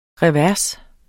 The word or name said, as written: revers